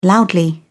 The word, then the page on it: loudly: British English